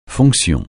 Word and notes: fonction: francais

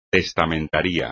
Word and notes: testamentaría: espagnol

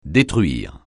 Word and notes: détruire: francais

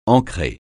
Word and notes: ancrer: francais

